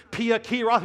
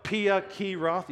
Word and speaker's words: Pi-hahiroth: You want to say that with me? Pi-Hahiroth, here we go. Pi-Hahiroth. Yeah, it's kind of weird, but that's kind of the Hebrew pronunciation of the word.